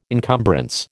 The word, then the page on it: encumbrance: American English